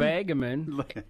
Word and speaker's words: Nebagamon: Nebagamon, a.k.a.